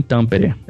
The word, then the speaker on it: Tampere: Bom, hoje eu moro em Tampere. É o jeito que se fala, é o jeito que se escreve.